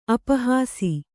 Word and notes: ಅಪಹಾಸಿ: apahāsi

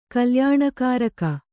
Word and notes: ಕಲ್ಯಾಣಕಾರಕ: kalyāṇakāraka

